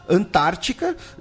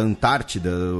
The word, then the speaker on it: Antártida: Lembrando que o Polo Sul também é o continente da Antártica, da Antártida, tem gente que pronuncia das duas maneiras, mas enfim.